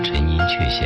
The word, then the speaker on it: 陈寅恪: 当年清华上下都叫他陈寅恪先生，然而在不少字典里没有雀这样的读音。